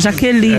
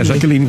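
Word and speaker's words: Jacqueline: É, J-A-C-Q-U-E-L-I-N-E. – Então é Jaqueline. – É Jaqueline mesmo. – Então esse C é mudo. – É tipo Victor.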